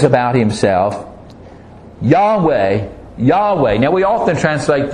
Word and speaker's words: Yahweh: And we know that it is pronounced Yahweh. We're pretty certain of that because Yah is found in the word Hallelujah.